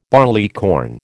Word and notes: barleycorn: American English

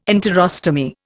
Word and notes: enterostomy: Indian

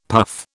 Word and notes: puff: British English

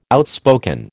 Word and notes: outspoken: American English

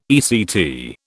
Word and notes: ECT: British English

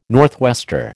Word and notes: northwester: American English

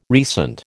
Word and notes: recent: American English

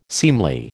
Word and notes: seemly: American English

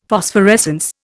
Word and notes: Phosphorescence: Indian

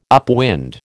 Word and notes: upwind: American English

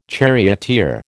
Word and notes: charioteer: American English

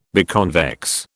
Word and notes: biconvex: British English

